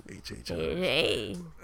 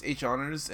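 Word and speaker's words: HHonors: Yeah, everywhere I go, HH honors. HH honors. – Or whatever, H. – No, it's H honors, and then you people, you're actually not supposed to pronounce the first H at all.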